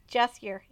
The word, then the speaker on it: Jaskier: Or is that how you pronounce it? Jaskier? – Jaskier, yeah.